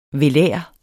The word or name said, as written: velar